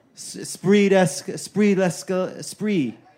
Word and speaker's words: esprit d'escalier: Esprit d'escalier.